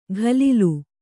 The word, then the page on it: ಘಲಿಲು: Kannada